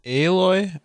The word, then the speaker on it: Aloy: Aloy, I think, is the correct pronunciation.